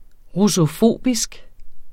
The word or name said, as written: russofobisk